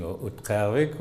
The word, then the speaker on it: Utqiagvik: Now, you've heard about the controversy a little bit about Barrow no longer being Barrow, but being Utqiagvik. Well, God's of you, if you have to pronounce it, is a little difficult. You know, it's a you kind of close your throat and kick some air out.